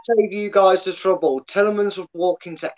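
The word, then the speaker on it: Tielemans: But I do like, what's his name for you guys? Talisman? I never know how to pronounce it. – Tillemans. – That's the one.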